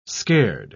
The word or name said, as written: scared